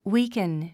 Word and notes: weaken: wíːkən; ウィークン

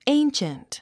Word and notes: ancient: éinʃənt; エィンシェント

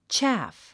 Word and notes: chaff: tʃǽf; チャァフ

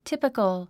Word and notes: typical: típikəl; ティピコゥ

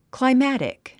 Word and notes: climatic: klaimǽtik; クライマァテック

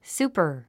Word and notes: super: súːpə r; スーパー

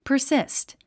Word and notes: persist: pə r síst; パァスィスト

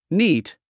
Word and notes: neat: níːt; ニート

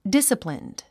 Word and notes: disciplined: dísəplind; ディッサプリンド

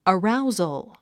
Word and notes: arousal: əráuz ə lz; アラウゾォ

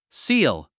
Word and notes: seal: síːl; シール

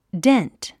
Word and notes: dent: dént; デェントゥ

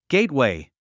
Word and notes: gateway: ゲイトウェイ

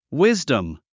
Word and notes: wisdom: wízdəm; ウィズダム

